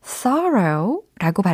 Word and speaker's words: thorough: who is fast, 재빠르고 and thorough 여기서 thorogh라고 발음이 됐죠? T H O R-O-U-G-H 라는 철자입니다.